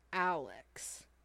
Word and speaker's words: Alyx: No, I'm not gonna pronounce this right, I don't think, but is it Half-Life Alyx? – Yes.